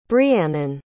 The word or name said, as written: briannon